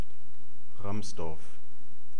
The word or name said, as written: ramsdorf